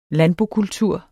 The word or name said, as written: landbokultur